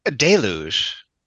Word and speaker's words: deluge: I said deluge, which maybe is not correct. – I think you said it right.